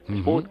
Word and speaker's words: Smoot: estaba el astrónomo George Snoot, Smoot, que siempre lo digo mal, estaba la directora del Instituto SETI, con la que tuvimos el placer de hablar, que es Jill Tarter.